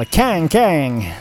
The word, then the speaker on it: King King: Red Devils där från både skivan och klubben King King, eller Kang Kang som man uttalar det om man är en Angelino som de tydligen är.